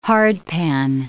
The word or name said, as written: hardpan